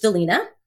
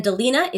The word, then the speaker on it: Dilena: So I have a YouTube channel, Coach Delina. – Oh. – Just like my name is spelled. – Okay. So spell it because your name is spelled differently. – Yes. So it's Coach, C-O-A-C-H. And Delina is D-I-L-E-N-A.